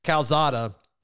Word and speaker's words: Calzada: But it sounds like Calzone – I got to start saying his name right. Calzada – First of all, it's amazing how widely renowned that is.